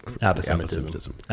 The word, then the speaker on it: aposematism: Aposematism.